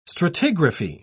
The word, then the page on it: stratigraphy: British English